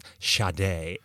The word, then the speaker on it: Sade: Well, they had the dividers for the albums, and on the one for Sade, underneath it, you know, in parentheses, it said pronounced Sade.